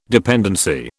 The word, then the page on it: dependency: British English